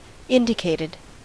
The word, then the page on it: indicated: American English